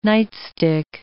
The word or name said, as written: nightstick